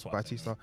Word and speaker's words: Bautista: The film was written and directed by James Gunn and stars the likes of Chris Pratt, Zoe Saldana, Dave Bautista? Is that how you say his name? – Bautista.